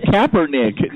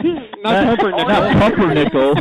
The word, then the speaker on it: Kaepernick: It's Kaepernick, not Pumpernickel.